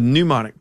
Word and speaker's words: pneumonic: It's spelled PNEU like pneumonia. – Mm-hmm. – Okay. So we're dealing with pneumonic plague.